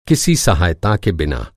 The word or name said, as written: के बिना